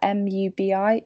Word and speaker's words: Mubi: It's MUBI, and I think it's pronounced Mubi.